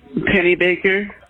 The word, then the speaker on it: Pennybaker: E-L-O-I-S-E? – Yes, ma'am. – Okay, and what is your last name? – It's Pennybaker. I tell them it rhymes with salt shaker, like the song.